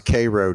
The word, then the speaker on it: Cairo: Cairo, Georgia is pronounced Cairo, Georgia.